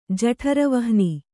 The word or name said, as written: ಜಠರವಹ್ನಿ